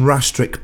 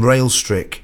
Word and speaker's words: Rastrick: Rastrick Brass Band. I pronounced that Brighouse and Railstrick the last time I played this tune, and was strongly reprimanded, if not admonished, by my sister Gina.